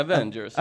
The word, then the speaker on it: Avengers: Adventures. Vad heter det? – Avengers. – Avengers, inte adventures.